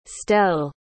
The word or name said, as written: stell